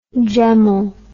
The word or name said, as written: gemel